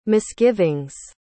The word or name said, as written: misgivings